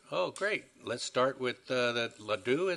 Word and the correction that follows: Radu Patrici: is it? Please correct my pronunciation, and then Diane, you're next up. – Yeah, it's Radu Patrici.